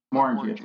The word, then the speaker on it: Glenmorangie: Apparently, I'm going to mispronounce it, and I apologize. Glenmorangie, is that what you said? – Morangie, sorry.